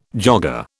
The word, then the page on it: jogger: British English